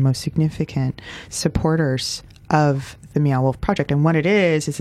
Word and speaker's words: Meow Wolf: And if you haven't heard of Meow Wolf, you may... – Is that spelt how it's pronounced? – Meow, like the sound a cat makes, and wolf, like the not a dog.